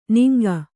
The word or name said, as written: ನಿಂಗ